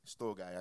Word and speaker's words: storge: Storge, that's the Australian version. Gavin would say it properly. Sturge, how do they say it, Gav? Storge, okay.